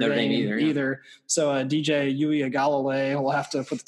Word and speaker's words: Uiagalelei: I've been working on that pronunciation. – Is that really how you say it? – Yeah. – Uiagalelei. Wow. I thought it was Ugalele.